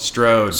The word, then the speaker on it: Strode: All right, I don't know if you want me to, but my biggest dislike of this entire movie is, in fact, Laurie Stroud. – Strode. – Strode. Jesus Christ. I get her name wrong all the time.